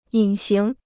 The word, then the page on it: 隐形: yǐn xíng